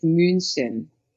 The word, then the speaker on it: München: Yeah, in English, it's Munich. In German, it's München.